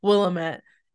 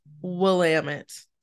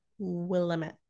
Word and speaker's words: Willamette: And I remember us having this conversation when I went to visit you, which is why I was like, I know how to say this the right way, even though I want to say Willamette. It's Willamette. – Yeah. Unless you ask David, he says that Portlanders say Willamette. – What are Courtlanders? – Portlanders?